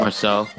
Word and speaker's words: Marseille: Marcel.